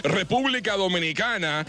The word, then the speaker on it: República Dominicana: República Dominicana.